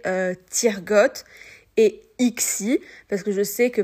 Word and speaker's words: Ixie: c'est tout simple, euh, ça se prononce, voilà, Tirgot et Ixi, il n'y a pas de, de grandes difficultés, vous le prononcez à la française et tout ira bien.